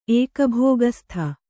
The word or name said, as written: ಏಕಭೋಗಸ್ಥ